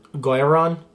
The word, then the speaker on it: Guiron: Because it looks, they said it that way in the film, but his name is spelled G-U-I-R-O-N, so you think it would be Gu-Iron or Gurin.